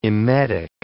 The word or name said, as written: emetic